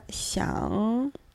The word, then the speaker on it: 想: слово «сян», «сян» третьим тоном, который состоит из дерева, глаза, и под ним пишется сердце.